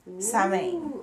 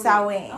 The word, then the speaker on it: Samhain: Wait, you said the S-O-W, and they said you emphasize it just like cow. So, sow, even though it's Sam. Samhain. It's Samhain. – Ah. – So, that's like how you say it.